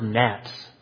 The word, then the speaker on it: gnats: I use the word gnats. And I've had so many mothers write me and say, "Why'd you use a silent G?"